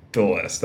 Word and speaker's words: Thewlis: Or David Thewlis? What is it? Thewlis, that's it.